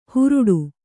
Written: ಹುರುಡು

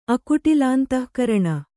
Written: ಅಕುಟಿಲಾಂತಃಕರಣ

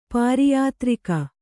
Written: ಪಾರಿಯಾತ್ರಿಕ

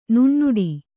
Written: ನುಣ್ಣುಡಿ

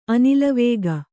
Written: ಅನಿಲವೇಗ

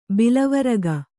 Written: ಬಿಲವರಗ

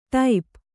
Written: ಟೋಪು

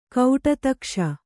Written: ಕಡೆದೆಗೆಸು

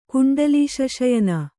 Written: ಕುಂಡಲೀಶಶಯನ

